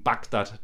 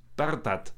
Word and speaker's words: Bagdad: Das schreibt man als R auf und das hinten, das G schreibt man als GH. Und trotzdem machen es aber selbst die Araber machen das auch so, dass sie ihre Dönerbuden Bagdad nennen. Und ich glaube, es ist eigentlich Bardat.